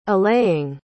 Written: allaying

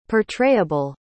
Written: portrayable

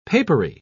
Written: papery

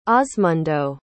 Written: osmundo

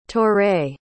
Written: toure

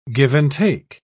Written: give and take

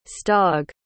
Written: stog

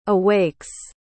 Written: awakes

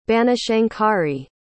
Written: banashankari

